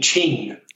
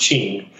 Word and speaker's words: Qing: Is it Gene Gwin Su? – Gene Ching Su. Chinese is with the way the Q is pronounced. So Jean Qing, so it's pronounced Qing. Jean Qing Su.